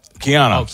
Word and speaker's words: Keanu: Am I saying his name right? Keanu Reeves? – Keanu Reeves. – Keanu. That's the way you say Keanu.